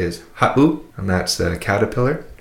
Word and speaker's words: ha7u: If you listen to that word ha'u, ha'u, it would be spelt H-A-7-U. If you were to take out that seven, it would be pronounced ha'u, but with the seven in there, makes that little glottal stop, that pause. So you say ha'u, ha'u.